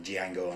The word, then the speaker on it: Django: To quote Calvin Candy from Django Unchained. – Yes, the D is silent, don't you? – Yes.